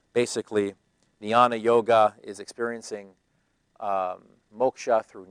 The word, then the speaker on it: jnana: They're called jnana yoga, spelled with a J, J-N-A-N-A, jnana yoga, which is usually practiced by the Brahmins.